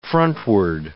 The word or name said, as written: frontward